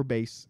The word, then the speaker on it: Bass: Paris Bass. Or base, depending on how you pronounce it.